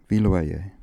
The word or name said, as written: Viloyer